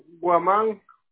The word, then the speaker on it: Guamán: Póngale a Rubén Guamán.